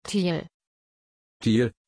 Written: Tijl

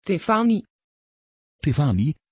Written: Tiffani

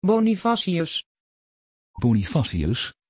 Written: bonifacius

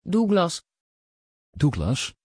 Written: Douglas

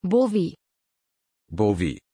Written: bowy

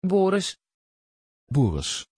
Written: boris

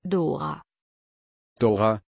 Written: Dora

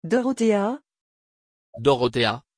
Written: Dorothea

